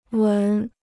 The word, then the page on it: 闻: wén